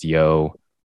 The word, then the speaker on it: Dzo: It's called Neon Nights Comedy Night, which follows by live karaoke at Dizo, spelled D-Z-O, pronounced Yo.